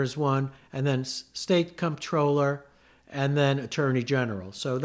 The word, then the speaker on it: comptroller: So what about, so we have comptroller, we have, am I pronouncing that right? Comptroller? – Yeah, it's spelled... – has a P in there.